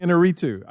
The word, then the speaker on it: Iñárritu: I think they give it to Iñárritu. Is that Iñárritu? – How you say it? Iñárritu.